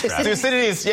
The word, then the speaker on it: Thucydides: Thucydides, yes.